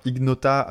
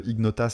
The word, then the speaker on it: Ignota: Alors Ignota, euh, je pense qu'on doit le prononcer comme ça, parce que Ignota, ça serait un peu espagnol, mais en fait euh, Ignota, c'est du latin.